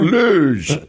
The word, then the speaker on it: Hugo: What was your name again, caller? – Luge. – Luge. – I think it's what I said. – Okay, Luge. – Which is spelled H-U-G-O.